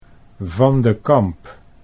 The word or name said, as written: Van de Kamp